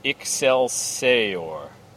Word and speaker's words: Excelsior: And they have it all written out how you're supposed to pronounce it. Excelsior.